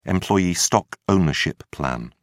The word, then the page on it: employee stock ownership plan: British English